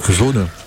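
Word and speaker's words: Sonne: C'est Sonnet. – Ah, zone. – Ouais. Oui, je l'ai prononcé comme il était écrit.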